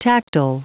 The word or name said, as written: tactile